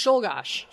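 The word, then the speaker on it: Czolgosz: I think it's pronounced Szulgosz.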